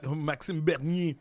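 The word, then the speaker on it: Maxime Bernier: Maxim Bernier.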